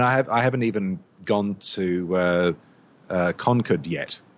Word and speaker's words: Concord: Because it's Massachusetts, it's pronounced Concord because they speak funny there.